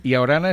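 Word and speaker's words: Ia Orana: Y Orana es el saludo. – Y Orana. Ah, lo hemos pronunciado mal. – Ah, no, que el problema de la I cuando lo escribe, la I mayúscula... – Claro. – Es una L, ¿no? – Yo lo he interpretado como una L.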